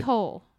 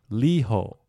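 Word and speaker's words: 你好: Diho. – Liho. – Oh, diho. – But it's pronounced like an L.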